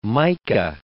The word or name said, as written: mica